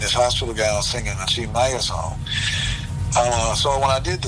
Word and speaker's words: Tim Maia: And Chi Maya, which how we spell it is Tim, T-I-M. Mm-hmm. But Chi Maya, which is T-I-M-M-A-I-A,